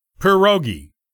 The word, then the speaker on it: pierogi: By the way, if you're wondering about the correct pronunciation, it normally goes like so. Pierogi. This is as opposed to pierogi or other pronunciations we sometimes hear in the States.